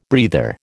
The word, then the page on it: breather: American English